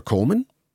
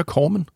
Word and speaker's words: Kormind: Corman? – Ja. – Navnebror til den amerikanske producer, Roger Corman? – Næsten. K-O-R-M-I-N-D, men det udtales på samme måde.